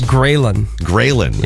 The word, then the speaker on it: ghrelin: Ghrelin.